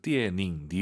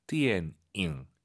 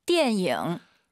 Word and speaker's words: 电影: 초급자분들께서 띠에닝 니은 발음으로 많이 해서 그래요. 띠엔잉 잊지 마시고요. 한번 더 들어볼게요. – 电影.